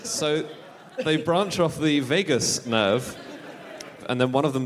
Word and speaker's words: vagus nerve: And in all mammals, they branch off the vagus nerve, is that how it's pronounced? Vagus nerve.